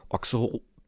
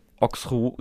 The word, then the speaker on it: Auxerrois: Wie spricht man sie auf? – Auxerrois. – Sehr oh. Du musst das Mikrofon verschlucken und Auxerrois.